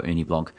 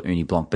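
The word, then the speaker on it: Ugni Blanc: I always decided, in my heart, I was always going to call it Uni Blanc, but then the form of the word Uni Blanc, so U-G-N-I, like Agni, if you're going to be an Australian phonetic pronunciation, is quite bold and it's offensive looking almost, but I also find it quite intriguing.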